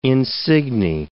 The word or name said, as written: insigne